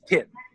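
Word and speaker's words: Thìn: That's T-H-I-N, but pronounced Tin.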